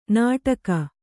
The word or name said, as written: ನಾಟಕ